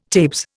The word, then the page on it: tabes: Indian